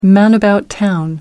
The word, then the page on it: man-about-town: American English